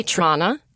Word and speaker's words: Tranna: Have I been saying your name wrong? You say Tranna. – Tranna. – I say Tranna, okay. – Yeah, like Anna Wintour.